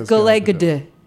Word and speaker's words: Gal Gadot: I heard it's Galay Gadot. Is that not it? How do we say it? – That's actually that was it.